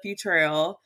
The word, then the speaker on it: Futrell: So my name is Kela Futrell. I like to leave Futrell out because people always butcher it. Like, is it Kela Future? Is it Futurer?